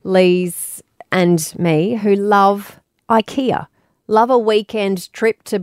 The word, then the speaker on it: IKEA: IKEA. Is the way we're meant to pronounce it, as a Swedish.